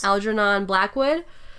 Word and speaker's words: Algernon Blackwood: I might butcher this. Algernon? – Yes. – Algernon Blackwood? – Mm-hmm.